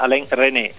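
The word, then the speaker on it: Alain Resnais: Se posso solo permettermi, si chiama Alain René, la S non si pronuncia, chiuso la parentesi.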